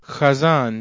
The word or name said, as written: chazan